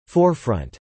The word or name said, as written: forefront